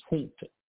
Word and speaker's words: junta: J-O-M-O, which stands for the, this term is actually pronounced junta, you know, but you know, in the English speaking world, you know, the letter J is sometimes not pronounced like the term has been understood, but it's the junta of military organizations. And the first word was, was J-U-N-T-A.